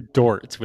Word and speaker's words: Dort: But you definitely see the kind of young rookie mistakes that happened from like Shai and Lou Gort. I can't even pronounce his name properly. – I think it's Lou Dort with a D. – Lou Dort. Yes, Dort. Excuse me.